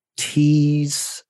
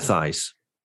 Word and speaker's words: Thijs: But either way, let's actually beautifully segue into the next one from Thijs van der Tween. – Thijs. – I'm sorry. Is it Thijs? Thijs? Like Thijs? – J is a what? J is a Y.